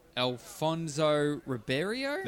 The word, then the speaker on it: Alfonso Ribeiro: Yeah, Alfonso Ribeiro. – That is correct. – Yep. – Alfonso Ribeiro.